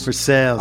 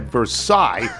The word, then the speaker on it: Versailles: Versailles. – Ironically, though, there is a hotel in the town called the Inn at Versailles that they pronounce like Versailles.